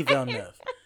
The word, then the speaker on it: Villeneuve: Denis Villeneuve.